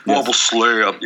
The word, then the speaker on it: Marble Slab: Marble Slab. You gotta say it like that.